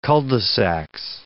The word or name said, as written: cul-de-sacs